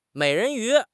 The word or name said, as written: mei3 ren2 yu2